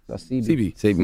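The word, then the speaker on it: Sebi: Sabi is. – 100%. – Dr. Sabi. – Dr. Sabi. – CB. My bad, I said it wrong.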